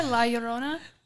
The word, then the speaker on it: La Llorona: Is that not how you pronounce it, La Llorona? – No, the proper pronunciation. – You said it right. – Yes, you said it right. – You have to roll that R, baby.